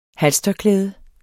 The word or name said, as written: halstørklæde